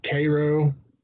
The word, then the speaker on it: Cairo: which is spelled like Cairo, but you pronounce it Cairo, because dirt.